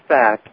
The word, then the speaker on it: PHACT: So it's pronounced fact, but it's spelled with a P-H.